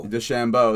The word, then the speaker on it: DeChambeau: DeChambeau, there we go.